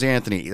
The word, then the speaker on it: Anthony: My name's Anthony." That's spelled A-N-T-H-O-N-Y.